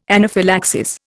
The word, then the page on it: anaphylaxis: Indian